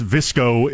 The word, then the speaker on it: VSCO: VSCO, pronounced Visco, is also a photo editing app that became popular on Instagram.